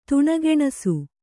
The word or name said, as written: ತುಣಗೆಣಸು